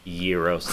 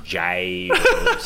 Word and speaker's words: gyros: And mad respect to Trey for pronouncing it Euros instead of gyros.